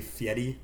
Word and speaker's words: Fieri: Knowing that at some point in his career, when he was already famous, he was like, hey guys, it's actually Fieri.